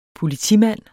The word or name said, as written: politimand